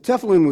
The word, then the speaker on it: tefillin: For your notes, it's spelled T-E-F-I-L-L-I-N, tefillin, on their right arm and on their forehead between their eyes.